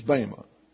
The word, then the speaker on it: bema: But what's being translated is a four-letter word, which is bema. It's B-E-M-A. It's an eta, therefore pronounced as a long A.